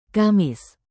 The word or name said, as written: kamis